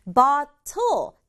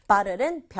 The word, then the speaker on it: bottle: 자, bottle. TT가 있잖아요. 이 bottle이라고 해도 되지만 중간에 있는 T는 소리가 약해진단 말이에요. 그래서 bottle보다는 bottle 이렇게 약하게 발음하면 훨씬 자연스러워요. bottle은 병, 그리고 paper.